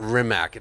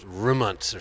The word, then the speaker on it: Rimac: It's not Rimac. It's pronounced Rimunts or something. – Right. – It's a Croatian. Croat word.